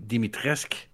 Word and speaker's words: Dimitrescu: Nou, je begint dus met, eh, met het kasteel waar Lady Dimitrescu. Die, die, u, die, u is silent, heb ik begrepen van het spel.